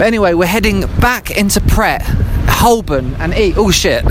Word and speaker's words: Pret: Make sure you say the T, and don't say manger. And if all else fails, just go with Pret, rhymes with Brett.